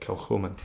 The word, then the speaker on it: Kilchoman: Kilchoman.